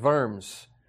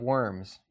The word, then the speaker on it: Worms: In 1521, Martin Luther, a name that most of you will know, stood before the Diet of Worms, or if you read it as it would be pronounced in English, the Diet of Worms.